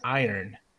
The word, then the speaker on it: iron: it's spelled iron, but it sounds like I earn money.